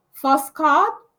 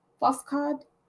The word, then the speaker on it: Foscadh: So it's called Shelter, but the Gaelic pronunciation is Foscad. Foscad. F-O-S-C-A-D-H.